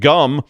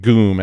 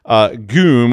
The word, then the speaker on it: GUM: So the next time you call support and it's being outsourced and you're using GUM, or I think it's pronounced GOOM, actually, GOOM, which is a big department store chain in Russia, you might be talking to an incarcerated IT specialist.